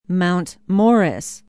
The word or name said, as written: Mount Morris